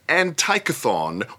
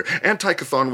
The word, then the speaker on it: Antichthon: Antikython is pronounced with a hard C.